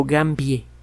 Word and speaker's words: gambiae: El Anopheles gambiae o gambie, como sería una de las dos pronunciaciones del latín.